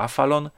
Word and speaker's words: Aphalon: Afalon. Pisze się to, proszę Państwa, A-P-H-A-L-O-N. Jak nie mam, czytało się to Afalon, Role Playing Game, nowy przecinek, polski system RPG.